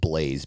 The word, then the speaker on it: Blayze: I believe it's pronounced Blaze, BLAYZE coaching.